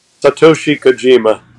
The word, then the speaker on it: Satoshi Kojima: Satoshi Kojima.